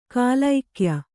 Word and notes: ಗೆಲುಗಯ್ಯ: gelugayya

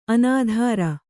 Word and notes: ಅನಿತರ: Kannada

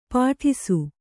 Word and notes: ಪಾಟಿಸು: Kannada

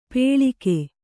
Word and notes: ಪೇಳಿಕೆ: Kannada; pēḷike

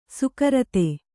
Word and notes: ಸುಕರತೆ: sukarate

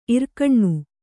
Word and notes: ಇರ್ಕಣ್ಣು: irkaṇṇu

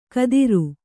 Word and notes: ಕದಿರು: kadiru

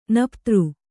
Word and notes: ನಪ್ತಾರ: naptāra; Kannada

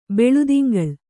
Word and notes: ಬೆಳುದಿಂಗಳ್: Kannada

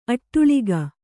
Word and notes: ಅಟ್ಟಾಲಕ: Kannada

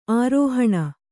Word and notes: ಆರೋಹಿಣಿ: ārōhiṇi

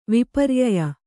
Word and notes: ವಿಪರ್ಯಯ: viparyaya

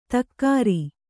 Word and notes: ತಕ್ಕಾರಿ: takkāri

